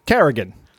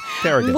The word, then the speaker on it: Carrigan: Is it Carrigan or Corrigan? – Carrigan, with an A, yeah. Carrigan. – Welcome to the show, Joe. – I said it, and then I wasn't sure. – Nope, that's you said it right the first time.